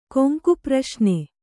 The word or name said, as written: ಕೊಂಕುಪ್ರಶ್ನೆ